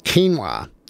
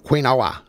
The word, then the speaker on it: quinoa: If I was to look at the word, I would pronounce it quinoa. So Q-U-I-N-O-A, quinoa, not quinoa, as I was pronouncing it. Quinoa is how it's pronounced.